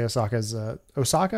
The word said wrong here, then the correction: Ahsoka: Am I saying that right? A- Ahsoka. – Ahsoka. Ahsoka. Ahsoka. Oh, Ahsoka.